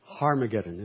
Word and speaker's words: Harmageddon: The Greek word has a, what we call a harsh breathing mark in front. So it, to be pronounced, it would be more accurately pronounced Harmageddon, as if it had an H.